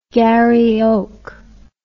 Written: garry oak

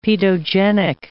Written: paedogenic

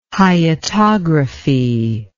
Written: hyetography

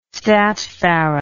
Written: statfarad

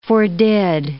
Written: fordid

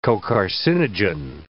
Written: cocarcinogen

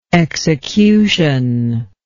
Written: executionary

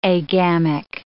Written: agamic

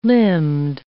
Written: limbed

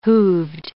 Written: hooved

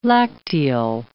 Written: lacteal

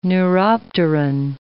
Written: neuropteran

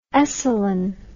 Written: Esalen

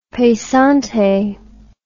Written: pesante

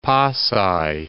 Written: Pasay

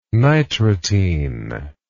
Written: nitratine

